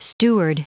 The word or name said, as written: steward